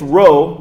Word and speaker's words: Rhos: It's Rhoe, but it's spelled R-H-O-S in Wales.